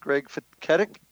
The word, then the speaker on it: Greg Feketik: And welcome back for this final session here tonight on Paranormal Stakeout with my guest, Greg Feketik.